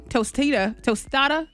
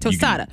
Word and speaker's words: tostada: Tostada. That's how you say it. Tostada.